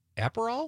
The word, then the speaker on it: Aperol: Liz from Sun Prairie says she's going to have tequila and soda with a lime or a splash of Aperol.